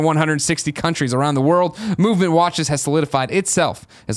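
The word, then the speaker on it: MVMT: Movement Watches, pronounced Movement, was founded on the belief that style shouldn't break the bank.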